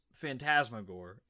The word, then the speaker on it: Fantasmagorie: The first animated film was in 1908, and I think it's pronounced Fantasmagorie. It was French.